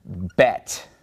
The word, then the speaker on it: Bet: And this letter is called Bet. Bet. Now, if we were to remove the dot from the letter Bet, because the letter Bet makes a B sound, b-b-b-bet, all right?